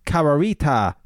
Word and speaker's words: Carorita: Kararita, pronounced Kararita, C-A-R-O-R-I-T-A.